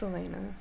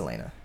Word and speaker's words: Elena: I always said Alana. – It's Elena. – How do you know it's Elena? Did you watch it? Because I know somebody whose name is spelled exactly like that, and she pronounces it Elena.